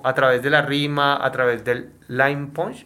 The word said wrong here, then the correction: punchline: punch? El punchline. – Ok, lo dije mal.